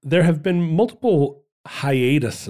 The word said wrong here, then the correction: Haiti: it's pronounced Haiti.